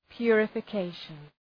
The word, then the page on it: purification: ,pjʋrəfə’keıʃən